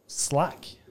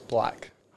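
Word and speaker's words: 6LACK: The nominees are Since I Have a Lover, Slack. Do you think that's how you actually say it? – I'm pretty sure it's Black. I know I say it like Black.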